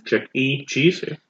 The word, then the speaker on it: Chuck E. Cheese's: Chuck E. Cheese.